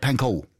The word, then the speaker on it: Pankow: Is that how you say it? Pankow? – Pankow.